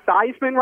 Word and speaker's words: Theismann: Theismann rhymes with Heisman. – Right. – I lived through that. – They changed the pronunciation of his last name. The real pronunciation is Theismann.